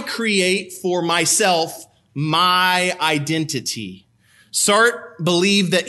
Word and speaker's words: Sartre: You might have thought it was Jean-Paul Sartre, like I thought my whole life, but it's Sartre. It rhymes with blart.